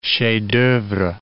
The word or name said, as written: chefs d'oeuvre